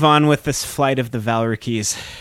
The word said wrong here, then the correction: Valkyries: All right, uh, Valkyries.